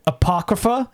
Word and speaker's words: Apocrypha: There's a video, Ed Wood Apocrypha. Am I saying that right? – That's right.